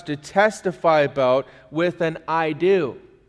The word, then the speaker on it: eido: It's spelled E-I-D-O. It's pronounced I do.